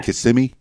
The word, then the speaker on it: Kissimmee: You went to high school in in Orlando in, in or Kissimmee, uh, or I guess it's yeah. Kissimmee.